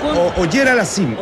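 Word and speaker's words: Auger-Aliassime: Pues es, disculpe la pronunciación, Auger Aliassime. – Es Auger Aliassime, el canadiense. Aliassime. Félix Auger Aliassime.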